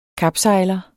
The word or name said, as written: kapsejler